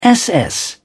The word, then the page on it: ss: British English